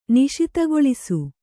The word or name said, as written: ನಿಶಿತಗೊಳಿಸು